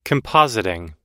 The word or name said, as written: compositing